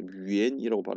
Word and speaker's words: 위안화: 어, 최근에 이제 위안화 왜 위안화라고 하는지 뭐 위엔이라고 발음하는데 위, 아무튼 위안화라고 항상 읽고